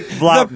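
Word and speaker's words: Vlautin: Yeah, I was going to say it's pronounced Free Willie. – It's like Free Willie like Vlauten.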